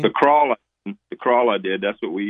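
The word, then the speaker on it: Kroil: And it's pronounced Crawl. Crawl, Crawl, whatever. I call it Crawl, like crawling, but Crawl. – It's like it's oil with a K and an R. Crawl.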